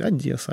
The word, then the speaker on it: Одесса: То есть Одесса, нужно говорить «Одесса, Одесса».